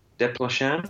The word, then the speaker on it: Desplechin: Desplechin.